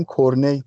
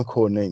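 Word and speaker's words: Cornet: که تلفظش کردن فکر کنم من الان گزارشگر فارسی نگاه نکردم که کورنت تلفظ میکردن یا نه ولی من گزارش انگلیسی داشتم میدم کورنی تلفظ میکردن حالا منم کورنی میگم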